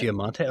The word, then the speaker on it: Giamatti: Spectacular show with Paul Giamatis, I think you pronounce it. – Giamatti.